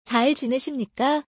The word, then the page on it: 잘 지내십니까: Korean